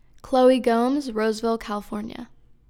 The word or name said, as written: Chloe Gomes